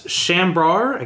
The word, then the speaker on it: Chambord: I don't think it's board, no. – Really? – I don't know. – It's bar. – He's like, Chambord? – Like... – Chambord. – I thought it was Chambord. – Well, that's how it's spelled, but that's not how it's pronounced. It's French.